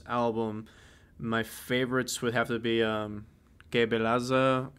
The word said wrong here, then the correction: Que Beleza: Jesus Christ, I butchered the fuck out of that. Whatever that yeah, one is. Um, um, it's Que Beleza and O Caminho do Bem.